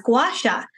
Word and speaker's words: gua sha: And I was like, oh, I never thought to look it up and actually see, was it pronounced a certain way? Lo and behold, it was gua sha.